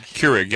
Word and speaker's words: Keurig: and I know I'm mispronouncing this, but is it Keurig? – Keurig.